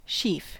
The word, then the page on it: sheaf: American English